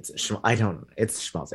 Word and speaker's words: Schmaltz: Schmaltz. It's a sh sound.